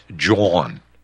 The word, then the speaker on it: Jean: Yeah, and by the way, Ron, I want you to pronounce my name the Pennsylvanian way, and that's John. Like Dawn.